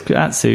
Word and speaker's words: Atsu: It is Atsu, yeah.